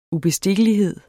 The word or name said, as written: UBESTIKKELIGHEDENS